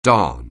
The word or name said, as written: darn